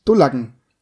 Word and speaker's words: Dullacken: Aussprache, Dullacken. – Und was ist das? – Dull. Also die Betonung ist auf jeden Fall aus dem U, auf dem U. Dullacken, Dullacken.